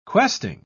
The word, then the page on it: questing: British English